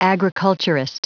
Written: agriculturist

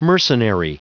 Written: mercenary

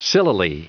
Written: sillily

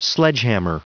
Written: sledgehammer

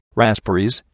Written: raspberries

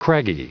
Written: craggy

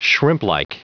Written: shrimplike

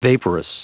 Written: vaporous

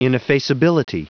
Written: ineffaceability

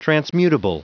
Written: transmutable

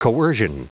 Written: coercion

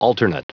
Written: alternate

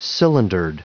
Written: cylindered

